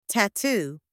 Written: tattoo

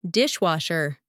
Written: dishwasher

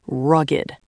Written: rugged

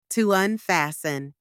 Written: to unfasten

